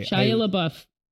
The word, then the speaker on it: Shia LaBeouf: But so Shia LaBeouf, am I saying it right? I'm sorry. – Shia LaBeouf. – Shia LaBeouf. I said it right.